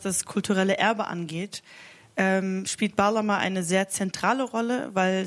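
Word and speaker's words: Bağlama: Da spricht man das G ja auch nicht aus. – Baglama.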